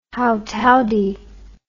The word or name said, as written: howtowdie